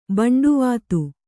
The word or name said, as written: ಬಂಡುವಾತು